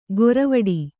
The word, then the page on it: ಗೊರವಡಿ: goravaḍi